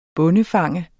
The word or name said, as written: bondefanger